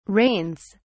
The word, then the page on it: rains: British English